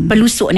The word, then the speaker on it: pelosok: Tapi sebenarnya P-E-L-O-S-O-K. – L-O. – L-O ya. – Bukan L-U. – Jangan. Kan? P-E-L-O-S-O-K. Tapi kita taklah menyebut pelosok. Tak adalah menyebut pelosok. Kita sebutannya pelusuk.